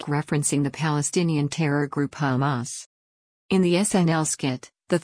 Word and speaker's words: Hamas: In doing so, they say they will support his music on social media, until they learn that his band is called Hamas, pronounced Hamuz.